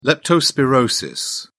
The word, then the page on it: leptospirosis: British English